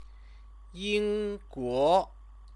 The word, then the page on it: yingguo: chinese